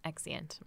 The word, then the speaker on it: exeunt: This is not how you pronounce it. – So wait, so exeunt? – Yeah, exeunt. – Mm-hmm.